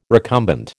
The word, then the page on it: recumbent: American English